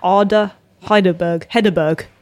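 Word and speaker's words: Ada Hegerberg: The obvious one that everyone's talking about, and rightly so as well, Arda Hedeberg.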